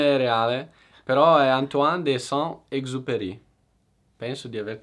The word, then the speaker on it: Antoine: Sì, ma anch'io non sono sicuro ragazzi, la pronuncia ma è di Antoine, quello so che è giusto, Antoine.